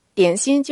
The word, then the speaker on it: 點心: 今天的句子是：一碗雲吞同兩籠點心，速速食完走人。一碗就是一碗，雲吞就是雲吞，雲吞同就是和，兩籠點心兩籠點心。點心就是廣式的點心，這裡注意發音，最後它是閉口的。